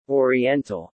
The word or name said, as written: oriental